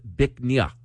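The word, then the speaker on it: Bích Nga: It's spelled B-I-C-H-N-G-A. It's pronounced Bích Nha, which loosely translates to beautiful jade.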